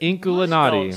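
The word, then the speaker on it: Inkulinati: Inculinati. – Spell that for me. – It's like Illuminati, but ink.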